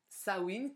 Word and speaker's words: Samhain: Alors, Sawin qui s'écrit pas du tout comme nous on écrirait Sawin, mais qui s'écrit Samain. S-A-M-H-A-I-N. Et il semblerait, alors je ne suis pas euh, très douée en langue, mais il semblerait que ça se dise Sawin, ce qui expliquerait le, le, mot Halloween notamment, euh, qui a été repris euh, aux États-Unis.